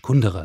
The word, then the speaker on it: Kundera: Kundera ist richtig.